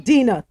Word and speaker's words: Dina: Dina.